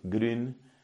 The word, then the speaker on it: Grün: que se escribe G-R-U-N, la U con diéresis, entonces es esa U alemana. Anselm Grün ha estado predicando aquí en la Argentina, parece que con cierto renombre, con cierto éxito.